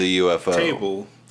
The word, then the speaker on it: ufotable: So, everyone, like, it's always pronounced as ufotable, but I heard a Japanese interview about the movie, like, a while back, and both the interviewer and the person from the studio were saying UFO table.